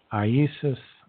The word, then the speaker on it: Iesus: In fact, if you look at the acronym, and you'll see this on the paintings of the crucifixions, there's an acronym, I-N-R-I, which is pronounced Iesus Nazarenus Rex Iodorum. In Aramaic, it's a completely different alphabet. In Greek, of course, is a different alphabet as well. But it was never Jesus. It was Iesus in Latin, Nazarenus Rex Iodorum, which today we say Jesus, the Nazarene King of the Jews.